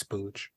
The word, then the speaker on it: spooge: So he goes into the front room thinking that he's going to run into fucking splooge. – There's no L, it's spooge.